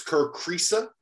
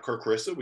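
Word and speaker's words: Kriisa: And it's Kerkrisa, not Kerkrisa. We got that one wrong, T.O.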